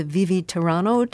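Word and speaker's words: Vivi, tiranno: This, this is from Handel's opera Rodelinda, and this is the Vivi Tiranno.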